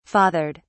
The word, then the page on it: fathered: British English